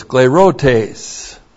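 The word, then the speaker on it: sklērotēs: Another noun that comes from the verb scleruno is sclerotes. S-K-L, long E-R-O-T, long E-S, sclerotes.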